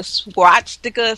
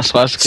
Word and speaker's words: swastika: Well, when he pulled the supervising nurse to the side, he rolled up his sleeve and showed that nurse the swastika sign. I'm probably saying that wrong. – No, swastika. You said swastika.